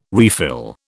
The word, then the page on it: refill: British English